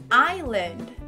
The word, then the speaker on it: island: E por que que essa palavra geralmente realmente gera muita confusão, porque esse S não é pronunciado. Se diz island.